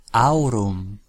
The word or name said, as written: aurum